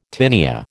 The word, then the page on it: tinea: American English